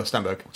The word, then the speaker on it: Stenberg: I think the central performances, particularly Ashla Stedberg, is great. But I think you've got a name wrong. – I've got a name, Amanda. – Amanda Stenberg.